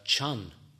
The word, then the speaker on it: Chan: In fact, it is the Japanese pronunciation of a Chinese character, which is pronounced Chan.